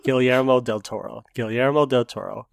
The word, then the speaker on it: Guillermo del Toro: How would you pronounce that again? – Guillermo I'm saying that word wrong too. People right now who are listening is like, man, these guys, it's Guillermo del Toro.